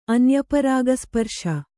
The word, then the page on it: ಅನ್ಯಪರಾಗಸ್ಪರ್ಶ: anyaparāgasparśa